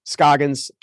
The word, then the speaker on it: Scoggins: In the meantime, my name is spelled Stephen with a PH, Scoggins, 2 G's.